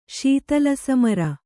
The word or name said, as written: ಶೀತಲಸಮರ